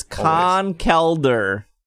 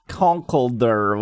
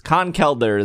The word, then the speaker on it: Conkeldurr: It is Conkeldurr, not Conkeldurr, which is the wrong way that I've said for many years. Conkeldurr is how you say it.